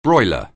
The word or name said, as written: broiler